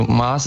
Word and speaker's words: Maß: Oh, erstmal glaube ich, eine Begrifflichkeit muss da geklärt werden, weil das kriegt man oft mit, dass das Wort Maß oft falsch ausgesprochen wird. Also ja. Es ist ein kurzes A und nicht Maß. Also Maß ist wirklich ein fataler Fehler.